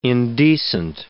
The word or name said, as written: indecent